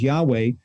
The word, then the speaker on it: Yahweh: But that name is thousands of times mentioned in the Old Testament, YHWH, and we believe the pronunciation is Yahweh.